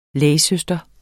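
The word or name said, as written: LÆGSØSTRE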